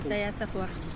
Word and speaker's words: Isaias Afwerki: Isaias Afwerki.